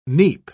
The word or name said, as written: neep